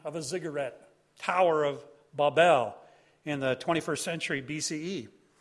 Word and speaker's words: Babel: By the way, it's pronounced Babel in Hebrew. I know some people say Tower of Babel, but it's Babel.